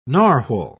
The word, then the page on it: narwal: British English